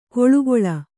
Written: ಕೋಳುಗೊಳ್

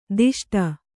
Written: ದಷ್ಟ

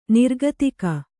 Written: ನಿರ್ಗತಿಕ

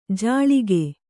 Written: ಜಾಳಿಗೆ

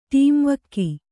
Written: ಟೀಂವಕ್ಕಿ

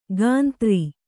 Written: ಕಂತ್ರಿ

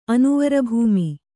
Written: ಅನುವರಭೂಮಿ

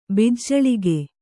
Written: ಬಿಜ್ಜಳಿಗೆ

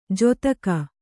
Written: ಜೊತ್ತಗ

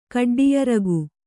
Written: ಕಡ್ಡಿಯರಗು

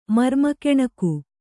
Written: ಮರ್ಮಕೆಣಕು

